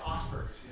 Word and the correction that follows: Asperger's: I don't think that's how you pronounce that. – Is it Asperger's? – Yeah.